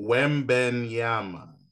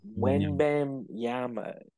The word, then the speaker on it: Wembanyama: Wembanyama. – Wembanyama.